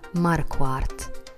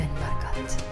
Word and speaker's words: Marquardt: Min veckans hot, som är fittigt hot, är um, fotografen och bouncern Sven Markat. Jag är inte helt säker på om man uttalar hans namn så. Mar Marquat det korrekta uttalet är Marquardt.